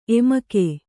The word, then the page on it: ಎಮಿಕೆ: emike